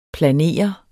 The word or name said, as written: planere